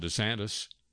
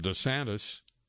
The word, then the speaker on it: DeSantis: The controversy is over how to pronounce his last name. Apparently, within the past year, he's altered the pronunciation from DeSantis to DeSantis.